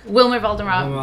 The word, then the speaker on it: Valderrama: Wilmer Valderrama.